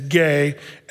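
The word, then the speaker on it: gay (γῆ): Well, actually, the word G-E in the Greek is gay, and then they attach that prefix to ology, the study of the earth, geology as a whole.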